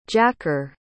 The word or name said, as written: jacker